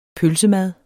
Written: pølsemad

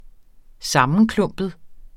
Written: sammenklumpet

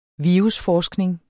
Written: virusforskning

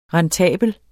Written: rentabel

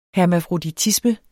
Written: hermafroditisme

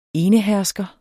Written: enehersker

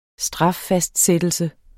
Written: straffastsættelse

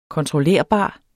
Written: kontrollerbar

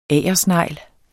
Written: agersnegl

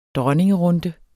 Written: dronningerunde

